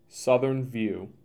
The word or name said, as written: Southern View